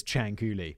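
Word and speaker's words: Cianciulli: But now I know her name is Ciancuilli, which is kind of a cool name. Leonarda Ciancuilli was born on April the 18th, 1893.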